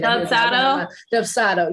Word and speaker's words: Dubsado: A lot of people always say Dubsado. I think that's how you say it. – Dubsado. – Yeah.